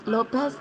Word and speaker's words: Lopez: Lopez, L-O-P-E-Z. – L-O-P-E-Z, right? – Yes, sir.